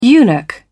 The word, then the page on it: eunuch: British English